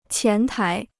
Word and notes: 前台: qián tái

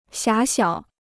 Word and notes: 狭小: xiá xiǎo

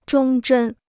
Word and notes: 忠贞: zhōng zhēn

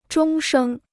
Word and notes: 终生: zhōng shēng

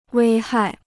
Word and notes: 危害: wēi hài

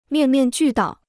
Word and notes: 面面俱到: miàn miàn jù dào

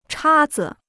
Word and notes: 叉子: chā zi

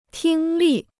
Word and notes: 听力: tīng lì

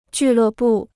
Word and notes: 俱乐部: jù lè bù

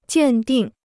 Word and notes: 鉴定: jiàn dìng

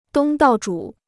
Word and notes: 东道主: dōng dào zhǔ

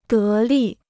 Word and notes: 得力: dé lì

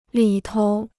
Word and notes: 里头: lǐ tou

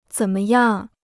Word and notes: 怎么样: zěn me yàng

